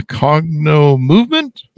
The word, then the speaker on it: Cognomovement: How do I pronounce this correctly, Katie? A Cognomovement? – You got it. Yep. Perfect.